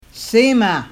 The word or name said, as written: semaa